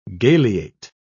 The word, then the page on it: galeate: British English